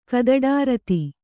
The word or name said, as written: ಕದಡಾರತಿ